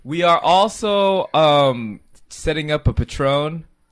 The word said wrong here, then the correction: Patreon: It's pronounced Patreon.